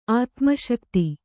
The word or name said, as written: ಆತ್ಮಶಕ್ತಿ